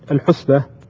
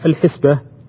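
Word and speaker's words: الحِسبة: أولا، الحسبة من الأخطاء الشائعة عند كثير من الناس أنه ينطق كلمة الحسبة الحسبة، وهذا في الحقيقة خطأ شائع، بل إنه للأسف يعني ربما نجده حتى عند بعض طلبة العلم ينطقها بهذه الطريقة. والنطق الصحيح لها لغة أن يقال الحسبة، فهي بكسر الحاء اسم من الاحتساب وليست بضم الحاء كما يقرأها بعض الناس كما قلنا قبل قليل.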